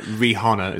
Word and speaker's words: Rihanna: So the idea is Debbie Ocean unites a team of misfit criminals that includes Awkwafina, Sarah Paulson, Kate Blanchett, Rihanna, Mindy Kaling. – Rihanna is how you actually pronounce it. – Rihanna, I believe, is how okay. kids pronounce her name.